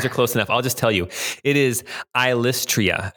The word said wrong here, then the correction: Ilystrae: Oh, no, Ilystrae. Ilystrae.